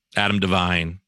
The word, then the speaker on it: Adam Devine: Adam Devine.